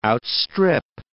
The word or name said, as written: outstrip